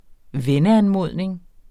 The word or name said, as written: venneanmodning